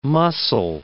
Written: muscle

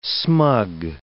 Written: smug

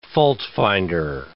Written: faultfinder

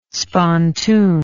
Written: spontoon

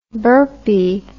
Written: burpee

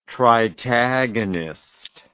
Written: tritagonist

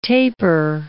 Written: tapir

